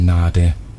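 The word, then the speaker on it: Nadé: Mein Name wird ganz unterschiedlich ausgesprochen. Ich selbst nenne mich Nade. Das wird geschrieben mit einem Accent aigu.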